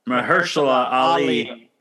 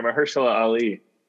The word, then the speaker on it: Mahershala Ali: Mahershala Ali. Yeah. Oscar winner, Mahershala Ali.